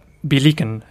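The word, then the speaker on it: Billiken: Se pronuncia Villican, en realidad. Porque es en inglés.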